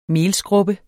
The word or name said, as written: melskrubbe